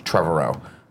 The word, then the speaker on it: Trevorrow: I think his last name is pronounced Trevorrow, but I could be wrong.